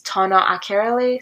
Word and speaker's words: Tona Akerele: My YouTube channel name is Tona Akerele, so that is spelled T-O-N-A space A-K-E-R-E-L-E.